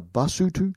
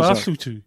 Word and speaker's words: Basotho: The people are the Basotho. One of them is Basotho.